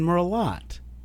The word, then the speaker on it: Merlot: So the next faux pas is pronouncing the T in Pinot Noir or the T in Merlot.